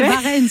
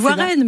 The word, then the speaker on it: Warren: Non, c'est Varenne, c'est Varenne qui m'a fait rire. – Ah bah c'est pas Varenne. – On dit Warren, mais enfin, vous le prononcez comme vous voulez.